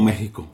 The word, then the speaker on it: México: Pues, nosotros lo conocemos como México. O sea, es como lo pronunciamos. – Con J.